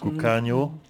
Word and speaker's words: Cucagno: On vient d'écouter Cucagna. C'est comme ça que ça se prononce. – Cucagno.